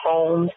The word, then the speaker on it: Holmes: And on Facebook is Sharika, spelled the same way, S-H-E-R-I-K-A, and the last name is Holmes, H-O-L-M-E-S.